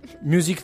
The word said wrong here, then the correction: Musik: zu einem Ritterballett. L'avrò pronunciato giusto? – Musik Totalmente a caso. Ritterballett. – Ah, okay, vabbè, c'è un, c'è un accento che ti frega.